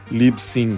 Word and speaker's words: Libsyn: Libsyn se escreve L-I, B de Brasil, S-Y-N de Noruega.